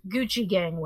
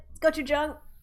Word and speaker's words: gochujang: Not Gucci Gang wings, but gochujang style spicy vegan wings. – Yep, almost got it. Gochujang.